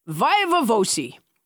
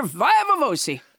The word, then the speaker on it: viva voce: How close to 100% are you on that pronunciation? – Pretty. Viva voce. – Viva voce. Because I look at that, and it feels more Italianate to me. It looks like viva voce.